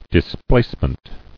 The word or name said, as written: Displacement